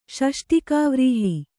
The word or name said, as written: ಷಷ್ಟಿಕಾವ್ರೀಹಿ